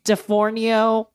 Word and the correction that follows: D'Onofrio: how do you say his name? D'Onofrio. That sounds like D'Onofrio.